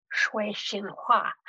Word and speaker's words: Sui Sin Far: which I'm not pronouncing in any correct way in terms of the Cantonese. But that is sort of how she's referred to. I think someone in China said to me, okay, if you really want to pronounce this correctly for your Chinese audience, it's Shui Xin Hua.